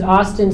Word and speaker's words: Austin: Okay, hey everyone, my name is Austin Serafin. That's A-U-S-T-I-N is my first name. My last name is spelled S-E-R-A-P-H-I-N.